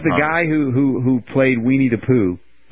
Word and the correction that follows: Winnie the Pooh: Weenie the Pooh? No, it's Winnie the Pooh.